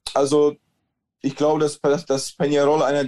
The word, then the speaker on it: Peñarol: Dein Club Atlético Peñarol, ich hoffe, ich habe das richtig ausgesprochen mit diesem Enye, Peñarol, genau, hat ja eine 100-jährige Fußballgeschichte oder eine Clubgeschichte im Fußball vor allem.